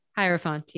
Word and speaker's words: Hierophant: Number five, the Hierophant. – Yes. – Did I say that right? – Hierophant, yeah.